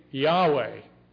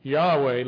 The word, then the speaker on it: Yahweh: But when we say Yahweh, we're not sure of the pronunciation actually, but we think that's how it was pronounced. Yahweh, Lord, all caps.